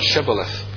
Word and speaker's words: Shibboleth: they were asked to pronounce this word, Shibboleth. The Ephraimites could not pronounce that word properly. They pronounced it, but they didn't pronounce the SH sound at the beginning. The word Shibboleth means ear of corn.